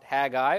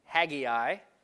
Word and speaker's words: Haggai: The prophet Haggai, or also called Haggai, both pronunciations are relatively correct. There's really not one correct one, but I like Haggai, so that's what we're going to call it.